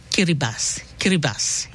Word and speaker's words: Kiribati: Him pronounce the same Kiribati, yeah? So the T-I in Kiribati and pronounced as S-I.